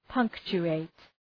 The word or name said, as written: punctuate